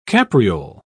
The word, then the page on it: capriole: British English